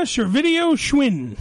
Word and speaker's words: Servidio-Schwinn: Elena Servidio-Schwinn. – Yeah, okay. – Yes. – I said it right. – It sounds like a video store on a bicycle.